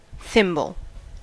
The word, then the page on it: thimble: /ˈθɪmbəl/